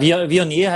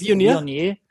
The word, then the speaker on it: Viognier: Oh, ich kann es nicht aussprechen, Leute. – Ja, Viognier heißt das. – Viognier. Das ist Viognier.